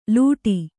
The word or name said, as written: ಲೂಟಿ